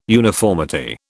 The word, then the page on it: uniformity: British English